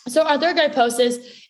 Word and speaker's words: arthrogryposis: And then I think just, you know, backing up a bit, let's talk a little bit about, you were born with arthrogryposis, right? Did I say that right? – Arthrogryposis.